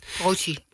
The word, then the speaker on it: Croce: Croce.